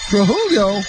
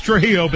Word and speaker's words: Trujillo: It's picked off by the Flying Dutchman, and back the other way comes Trujillo. I hope I said that right. – You didn't. It's Alex Trujillo, but that's okay because the transfer comes in and just he's in the way.